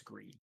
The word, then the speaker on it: Gris: Yeah, Gree, Greece, mm-hmm. whatever. – I think it's uh, Gris. I think they're French. – Okay. It's Gris. Silent S.